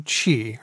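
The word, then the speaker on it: Qi: and that does include Lily Chi. Her last name is spelled Q-I, by the way, and she came here about 30 years ago.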